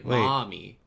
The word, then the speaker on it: Maumee: but I always say just like mommy, and everyone's like, oh, you're saying mommy? And I'm like, you want your mommy and your daddy? And I'm like, no, I live in the city of mommy. – What's it spelled like? – M-A-U-M-E-E.